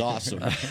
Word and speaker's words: Asim: He's a seam to me until he proves he's awesome. – But he pronounces it. – I know. – Awesome. – I know. Awesome. – I wonder, though, if indeed if it was pronounced Asim and then he got into college and his teammates and coaches said, awesome.